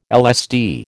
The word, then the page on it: LSD: American English